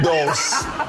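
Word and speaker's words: dulse: Dos. It's like pulse with a D.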